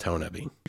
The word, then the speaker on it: Honobia: It's spelled Honobia, H-O-N-O-B-I-A, but I'm from the area, from Southeast Oklahoma, and it's pronounced Honubi.